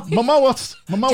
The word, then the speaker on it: Momoa: Momoa. – That's right. Jason Momoa. – Mimosa.